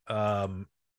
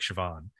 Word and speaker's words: Siobhan: Like, I think, Siobhan? I always have a hard time pronouncing that name because it doesn't look like Siobhan.